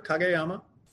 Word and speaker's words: Kageyama: Kageyama.